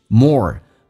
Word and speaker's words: more: The more you say that you don't love your life, the more 我们知道越什么什么越什么 more 美式发音当中嘴巴凸出来收得很圆，整个舌身往回卷，叫 or。 The more you say that you don't love your life, 你说的越多就是你人生你不太喜欢自己的人生。